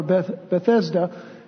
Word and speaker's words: Bethesda: And notice it says, which is called in the Hebrew tongue Bethesda, or Bethesda, the English pronunciation, having five porches.